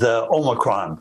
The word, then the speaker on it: Omicron: There's a lot of confusion over how to pronounce the new variant. – The newly discovered Omicron variant. – The rapidly spreading Omicron variant. – The Omicron variant. – So, which is right? Turns out they all are. Those are all acceptable ways to pronounce Omicron.